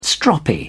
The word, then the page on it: stroppy: British English